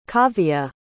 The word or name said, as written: kaavia